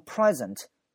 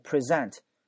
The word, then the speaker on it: present: 发音不一样。如果它是名词或形容词，读成 present，读成 present 就表示现在的形容词啊，现在的，或者是名词礼物。如果读成 present，重音放到 z 这个声音这个位置， present 放到 z 这个声音上面，这个声音前面，那就是动词，表示呈现。